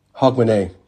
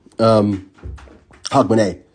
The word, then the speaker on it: Hogmanay: It's called Hogmanay, which I always mispronounce because it's just a strange word. Hogmanay. Did I mispronounce it? – Hogmanay. – Okay, my local companion just corrected me on the pronunciation. Hogmanay.